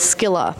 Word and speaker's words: Scylla: or the quite grotesque story of Scylla, or as we've been told, the correct way to say it is Scylla. Actually, it's sort of a hard K, or a hard C rather.